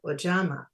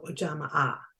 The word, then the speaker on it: Ujamaa: When the fourth day of Kwanzaa comes, it's time to celebrate Ujamaa or Ujamaa. Some people pronounce the double A, which stands for cooperative economics.